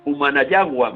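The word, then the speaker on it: Cumanayagua: en pueblos como Abreus o Humanayagua, me parece que lo he dicho bien, ¿es así? Sí, perfecto.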